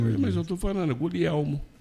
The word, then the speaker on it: Guglielmo: Guglielmo. Você não pronuncia o G. – Olha. – No italiano, a pessoa tem mania de pronunciar o G.